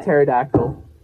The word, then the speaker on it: pterodactyl: It's like pterodactyl. – P is silent.